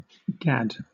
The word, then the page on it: gad: British English